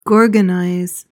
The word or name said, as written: gorgonize